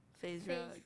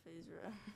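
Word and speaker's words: FaZe Rug: Knighton likes Phiz Rug. – Well, who is it? – Phase Rug.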